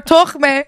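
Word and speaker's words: tokhmeh: We were like, no more tokhme. That's how you say it in Farsi.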